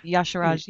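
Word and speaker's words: Y'Shaarj: Like, it's funny though, because the Titan seems to have managed it against Yashiraj. I can never say that name. – Yes, Yashiraj.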